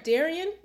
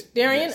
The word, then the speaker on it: Darian: Am I going to pronounce it right? Darian? Yes. Darian? Yes, okay. Yes. I want to make sure I pronounced it right.